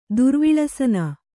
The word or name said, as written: ದುರ್ವಿಳಸನ